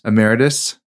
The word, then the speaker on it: Emeritus: Emeritus.